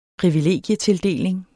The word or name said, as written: privilegietildeling